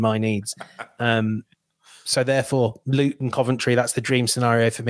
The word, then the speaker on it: Coventry: For our American listeners, it is pronounced Coventry.